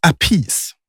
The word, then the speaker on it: Appice: Han heter Vinnie Appice. Och storebror då, Carmine, han heter Apice. Så det är alltså Carmine Appice. Oj, tre bröder med tre olika uttal på samma efternamn.